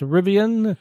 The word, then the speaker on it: Rivian: Take that to, was it Rivet, or how's that pronounced? Rivian, that has the $700 million investment from Amazon.